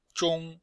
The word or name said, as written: zhong1